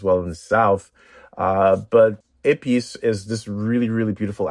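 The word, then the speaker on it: épis: So the first thing you said is you marinate chicken thighs in épices. So tell us about épices, and it's spelled E-P-I-S.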